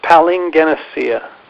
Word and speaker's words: palingenesia: The word is palingenesia. Did I pronounce it correctly? – You're really close. Palingenesia.